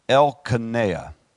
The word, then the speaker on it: Elkanah: Elkanah. Elkanah. It's kind of hard to say. I want to always call it Naya, but it's Elkanah.